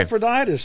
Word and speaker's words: Epaphroditus: Nobody can pronounce his name properly anymore. Excuse me for butchering it. – Yeah. No, that's it. That's okay. Epaphroditus, that's his name.